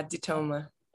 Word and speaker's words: DeToma: So you pronounce it DeToma? – Yeah, DeToma. – Okay, good. I was like thinking about a French, you know, pronunciation, because I think maybe because I'm like living at like so close to the border, so I'm like DeToma. – Yeah. Hey, that works. It's Italian, so you know, any of the Romance languages, it'll fit the vibe.